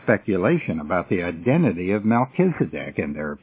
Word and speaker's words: Melchizedek: It is not Melchizedek, because in Hebrew, the CH sound is pronounced as a K, so it's Melchizedek.